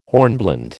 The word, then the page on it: hornblende: American English